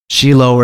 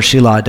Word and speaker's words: Shiloh: Shiloh or Shelah, depending on how the pronunciation is of that last vowel on the hey.